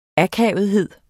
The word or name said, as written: akavethed